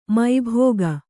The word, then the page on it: ಮಬ್ಬಿಗ: mabbiga